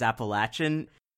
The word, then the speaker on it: Appalachian: As a West Coaster, I've heard periodically that the way you're supposed to say it is Appalachian. How should I be pronouncing that? – it can go either way. I hear it both ways here. Here we say Appalachian.